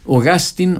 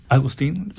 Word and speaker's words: Augustine: Que está relacionado con las cuerdas Augustine o Agustín, no sé cómo la pronuncian allá. – Agustín, sí. – Agustín, fíjate que en Inglaterra se la pronuncia Augustine. – Ah, sí, me imagino. Sí, ellos tienen otra pronunciación.